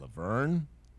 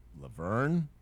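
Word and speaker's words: La Vergne: here in Rutherford County, one that is commonly mispr- mispronounced, Laverne, Laverne, Laverne. I've heard it pronounced Laverne J. – Yeah. Laverne. – Laverne. Yeah. And Laverne. I hear a lot. It is L small a space V E R G N E.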